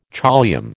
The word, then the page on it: choliamb: American English